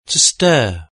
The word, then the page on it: to stir: British English